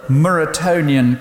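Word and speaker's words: Muratorian: The Muratonian canon, is that how you say it, Andrew? Yes, that's how Andrew says it, so we must be right.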